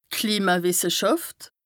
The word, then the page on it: d' Klimawìsseschàft: Bas-Rhin; Strasbourg